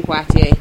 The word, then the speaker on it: Poitier: Sydney Poitier. – I call it Poitier because that's the French pronunciation.